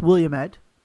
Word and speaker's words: Willamette: I believe it's pronounced Willamette. – Willamette. I actually have a friend that went to it. His way of saying it, he had a little phrase. It's Willamette, damn it.